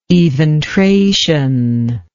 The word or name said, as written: eventration